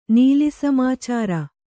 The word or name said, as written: ನೀಲಿಸಮಾಚಾರ